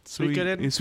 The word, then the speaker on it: Suikoden: Suikoden.